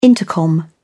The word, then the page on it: intercom: British English